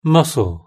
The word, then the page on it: muscle: British English